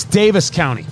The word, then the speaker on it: Daviess: The Davies County, D-A-V-I-E-S-S, County, finished in the French oak. So it's Davis County. My apologies. Because when I first saw it, it was Davies County. And then we started looking because I asked, am I pronouncing properly the bourbon that we're drinking, the Davis County Limited Edition Kentucky Straight Bourbon Whiskey, finished in the French oak casks. Nope, it's Davis County. My apologies, Davis County. I always want to get it right. I'd read it. I thought it was Davies. Davis County, D-A-V-I-E-S-S.